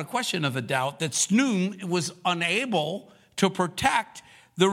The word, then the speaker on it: Khnum: It's spelled K-H-N-U-M, but it's pronounced Snum.